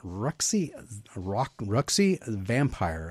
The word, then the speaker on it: Ruxy Vampire: First and foremost, you have a Kickstarter going on currently for your latest creation, Ruxy Vampire. I did say it right, right? – That is correct, okay.